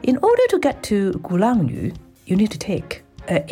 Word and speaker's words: Gulangyu: Today, I'm going to talk about Gulangyu.